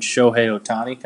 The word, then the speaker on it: Shohei Ohtani: The two names, the two names you got out there right now so far, Giancarlo Stanton and Shohei Ohtani, coming over from Japan.